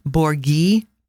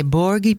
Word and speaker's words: borghi: Okay, and Borghi, just so you know, in case you want to know, is spelled B-O-R-G-H-I. H is silent.